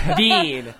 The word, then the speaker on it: Sabine: They even have the pronunciation because I mispronounced it the first time. It's like, no, like this dummy. – Yeah. – Sabine. Like bean.